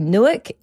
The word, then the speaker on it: Newark: We did an episode a few episodes ago and we couldn't actually figure out how to say this place. It's actually Newark, N-E-W-A-R-K.